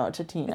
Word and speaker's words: Tatine: They had this brand called Tatine. I think that's how you say it. T-A-T-I-N-E. Maybe it's Tatin. No, Tatine. – It's gotta be Tatine. Yeah.